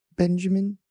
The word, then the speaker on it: Benjamin: Yes, my name is Benjamin.